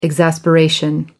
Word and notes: exasperation: American English

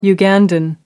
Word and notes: ugandan: American English

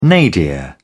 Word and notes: nadir: British English

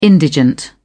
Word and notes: indigent: British English

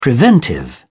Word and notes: preventive: British English

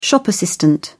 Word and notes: shop assistant: British English

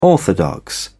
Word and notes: orthodox: British English

